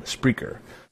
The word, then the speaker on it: Spreaker: Yes, Spreaker is a thing. I did not say that wrong. Spreaker.